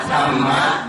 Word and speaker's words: Dhamma: Paramattha Dhamma hai ba. Hai ba. Paramattha Dhamma. Cái chữ này đọc là Dhamma. Dhamma là pháp, dịch là pháp.